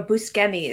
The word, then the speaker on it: Buscemi: Was this the first time and you guys saw Steve Buscemi or Buscemi as he would pronounce it, it, Yeah, Buscemi.